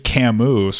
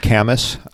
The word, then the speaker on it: Camus: And if you really think about it in life as well, because if you read Camus, some like depressing existential philosophy. – It's pronounced Camus.